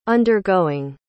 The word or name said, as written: undergoing